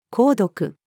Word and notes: 購読: female

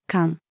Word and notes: 管: female

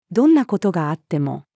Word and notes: どんな事があっても: female

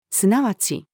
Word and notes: 即ち: female

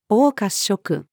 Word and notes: 黄褐色: female

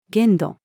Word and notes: 限度: female

